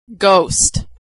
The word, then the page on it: ghost: g